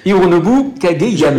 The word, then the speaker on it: Hironobu Kageyama: Hironobu Kageyama.